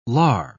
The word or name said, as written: lar